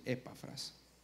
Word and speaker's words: Epafras: Resulta y pasa que ahí hay un discípulo de Pablo que se llamaba, inclusive a veces le pongo tilde, Epafras. Así se pronuncia, Epafras.